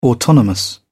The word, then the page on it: autonomous: British English